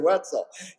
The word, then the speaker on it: Wetzel: I remember his name because it rhymes with pretzel. Mr. Wetzel, he was a track coach and I liked to run, so there was a lot of things there, but yes, okay.